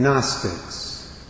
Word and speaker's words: Gnostics: Or do you want the ones that were written much later by the so-called Gnostics? That word is spelled G, capital G, N-O-S-C-I-C-S. The G is silent.